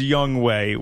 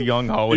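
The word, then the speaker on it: Younghoe: Oh, We know it's Young Way. We know, yes. But it's spelled Young Ho, and yeah. That's the way we're going to say it because that's hilarious. Young Way is not as funny as Young Ho, as you well know.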